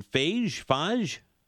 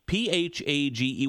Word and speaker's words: Phage: The book is called, and I know I'm going to butcher the pronunciation, Phage? – Yeah, you've got it. Exactly. That's it. – Phage.